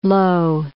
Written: Loewe

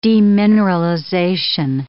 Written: demineralization

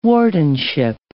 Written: wardenship

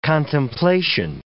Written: contemplation

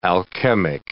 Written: alchemic

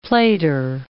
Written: plater

